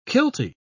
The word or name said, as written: kiltie